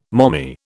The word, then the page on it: mommy: British English